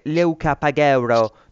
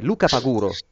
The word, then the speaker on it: Luca Paguro: Non è che Leuca Pagheuro, No, no, è Luca Paguro è sì, proprio sì.